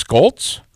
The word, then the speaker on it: Scholtz: When we come back, hour number two, that's where we're going to find, you said it's Scholtz? Mathew Scholtz? – That is the correct pronunciation.